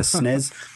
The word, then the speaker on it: SNES: I think some people prefer it as with a kind of soft S at the end, like a Z, so like a SNES. Which doesn't work for me, because system, the word system, it starts with a sharp, hard S, doesn't it?